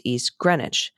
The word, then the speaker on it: Greenwich: Ditto for East Greenwich, pronounced East Greenwich.